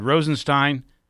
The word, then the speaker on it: Rosenstein: They're also attacking the Justice Department and Deputy Attorney General Rod Rosenstein, it's pronounced Stein, for the Mueller investigation of Russian influence in the 2016 Trump campaign and possible obstruction of justice by the president.